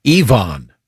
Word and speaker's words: Evon: Ryan is referring to a letter we got previously from Evon, whose name we pronounced Evan, even though it's E-V-O-N. We just assumed it was an alternate spelling of Evelyn. He's Evon.